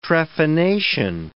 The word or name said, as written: trephination